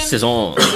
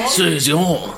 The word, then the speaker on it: Saison: Okay, we are rolling with the King Charlemagne, which is a Saison. Is that how you pronounce it? – Saison. – Saison. – French.